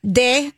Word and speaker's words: Deux: De Magu. – And okay. – It's D-E-A-U. Yeah. No, D-E-U-X. – Okay, and that's de. – Cafe. – Yeah. – And then D-E-U-X. – Yeah. – D-E. And then M-A-G-O-T-S.